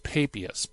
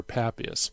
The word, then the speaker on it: Papias: And sometimes I hear it pronounced Papias, but I prefer Papias.